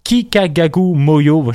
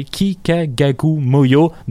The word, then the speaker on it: Kikagaku Moyo: Je pense que c'est Kikagaku Moyo.